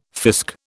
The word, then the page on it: fisc: British English